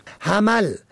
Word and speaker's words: حمل: حمل به فتح و میم ماه اول سال شمسی است که شماری آن را ماه حمل می خوانند که سخت نادرست است. کلمه حمل